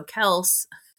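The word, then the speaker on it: Kelce: Everyone says it's Kelsey.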